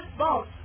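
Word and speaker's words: spouse: 这个单词叫 couple 是夫妇，还有一个单词表示夫妇的单词叫 sport，看见发音你就知道了，都抱在一起哈，sport，好，是不是叫夫妇配偶？好，sport，好，记得是发音它读成 sport，好，但是为了学它的音 sport，好，那感觉呢是不是？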